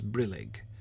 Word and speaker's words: brillig: I think brillig is clearly with the stress on the first syllable.